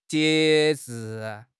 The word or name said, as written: 节制